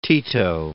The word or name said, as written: Tito